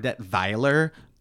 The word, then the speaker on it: Detweiler: I was Max Detweiler, or Detweiler, which is how you're supposed to pronounce it, apparently, who was Uncle Max in the movie.